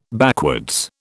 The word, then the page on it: backwards: British English